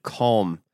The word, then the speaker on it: calm: it's so wide open and calm. I'd focus on like calming stuff. – I like that you pronounce the L in calm.